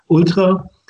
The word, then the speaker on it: ultra: but let me pronounce it German, ultra.